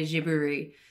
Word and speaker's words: Ghibli: So if you want to say it like Hayao Miyazaki, then you've got to say Jiburi.